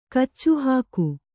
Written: ಕಚ್ಚುಹಾಕು

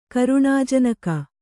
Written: ಕರುಣಾಜನಕ

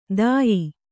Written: ದಾಯಿ

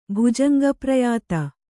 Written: ಭುಜಂಗಪ್ರಯಾತ

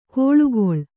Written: ಕೋಳುಗೊಳ್

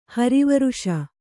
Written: ಹರಿವರುಷ

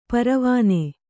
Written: ಪರವಾನೆ

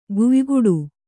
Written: ಗುಯ್ಗುಡು

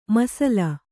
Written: ಮೀಸಲು

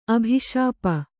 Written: ಅಭಿಶಾಪ

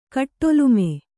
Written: ಕಟ್ಟೊಲುಮೆ

